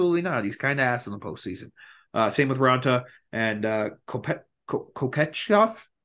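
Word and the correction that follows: Kochetkov: How do you say his name? – Koshikov. – Koshikov.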